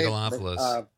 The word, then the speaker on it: Megalopolis: and the Francis Ford Coppola movie, Megalopolis. Am I saying that right? – Megalopolis.